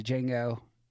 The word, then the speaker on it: Django: And that's taking place in Django. I'm saying that wrong. Yeah. In Django. – There's a great line where he says, the D is silent, hillbilly.